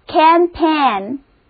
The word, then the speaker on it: campaign: 因为啊是来源于法语的一个词，所以字母 G 在这里不发音。前一个音节中的 A 还是发那个梅花音，第二个音节中里的字母组合 AI 发 A 的音。一起练习一遍怎么样？整个单词读作 campaign，词根 CAMP 就是田野的意思，后缀 AIGN 也含有平原的意思。